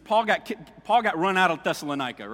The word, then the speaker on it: Thessalonica: Thessalonica is right here.